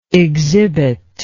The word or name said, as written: exhibitable